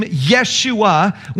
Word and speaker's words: Yeshua: The Hebrew name Yeshua, when it becomes Greek, it's pronounced Jesus. When it then gets transformed through the ages of history, the Ye at the beginning of the word turns into a J, and we then say Jesus.